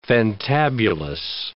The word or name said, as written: fantabulous